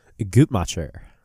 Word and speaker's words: Guttmacher: Guttmacher.